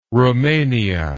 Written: roumania

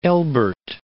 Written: Elbert, Mount

